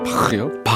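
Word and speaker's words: Bach: 바흐, 바흐요? – 바흐. 예, 네, 독일 발음 바흐.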